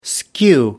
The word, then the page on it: skew: American English